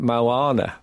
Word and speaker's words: Moana: And then I realised you actually pronounce it Moana.